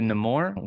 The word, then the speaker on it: Namor: But I'm really looking forward to Namor, which is the new official pronunciation of that character's name.